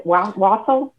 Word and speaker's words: wassail: And is that how you pronounce it? Wassail? – Wassail. – Wassail. Wassail. There you go.